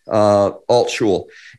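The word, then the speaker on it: Altschul: Altshul is a bit of a tongue twister, so I'm going to spell it. It's A-L-T as in Tom, S-C-H-U-L, Altshul.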